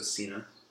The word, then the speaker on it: Cynia: Personally, I pronounce it the German way, which is the second vowel is long. So Cynia.